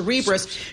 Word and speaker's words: Cerebras: How do you say your name? Like Cerebral. – Yes. – So Cerebras shares soaring.